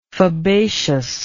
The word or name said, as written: fabaceous